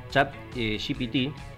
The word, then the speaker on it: ChatGPT: Les contaba hace un ratito lo del tema del taller de ChatGPT.